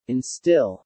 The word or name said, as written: instill